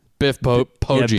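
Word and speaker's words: Poggi: Oh, see, but the problem is I know this is a coach and you pronounce his name wrong, by the way. – Oh, nuh-uh. – Biff Poggy. – Yeah, Biff. Poggy.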